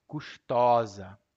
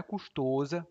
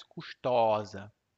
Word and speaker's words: custosa: A pronúncia do masculino é custoso. Por exemplo, um curso muito custoso. Mas o feminino é custosa. Não é custosa, mas custosa.